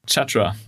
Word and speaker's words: Chatra: Oder Chatra, wie spricht man es aus? – Chatra, ja, genau. Chatra.